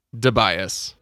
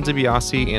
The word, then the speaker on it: DiBiase: And it is not pronounced Debias.